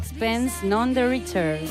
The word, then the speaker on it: Sixpence None the Richer: Sixpence None the Richer.